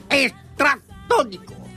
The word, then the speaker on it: Estratónico: Con el acento en la primera O. Estratónico.